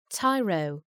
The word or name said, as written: tyro